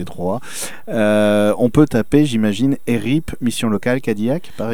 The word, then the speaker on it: ERIP: ERIP, E-R-I-P...